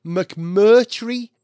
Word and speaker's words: McMurtry: The car is called the McMurtry Spearling.